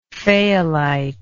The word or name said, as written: fayalite